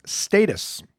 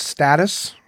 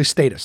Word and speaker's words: status: It's supposed to be pronounced status. So if you're like me and you pronounce status, then you're wrong. It's actually status.